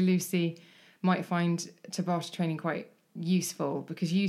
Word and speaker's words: Tabata: No, Tabata training. Am I saying it wrong? – No, that's it.